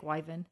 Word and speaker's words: Wyvern: No, I don't think the R is pronounced from what I've seen. But that's according to Oxford English Dictionary. Wyvern. – Okay, I'll take Wyvern.